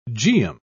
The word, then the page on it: geum: British English